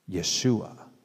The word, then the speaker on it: Yeshua: Jesus is our translation of the name Yeshua. Yeshua. It sounds similar almost to Joshua, but it's spelled with the letter Y as in yellow, E-S-H-U-A.